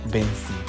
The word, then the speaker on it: BNXN: I don't know how he wants us to pronounce it, but he said it is pronounced Benson.